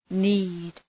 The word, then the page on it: knead: ni:d